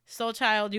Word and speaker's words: SLCHLD: Soul Child, you out here. And for those that you don't know, it is spelled S L C H L D, okay?